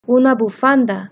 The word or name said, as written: una bufanda